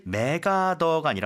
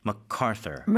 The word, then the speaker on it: MacArthur: 사실 맥아더가 아니라 원래 발음은 MacArthur가 MacArthur. – 맞겠죠.